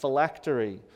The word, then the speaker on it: phylactery: It's a hard word to pronounce. It's not pterodactyl. It's not anything like that. Phylactery.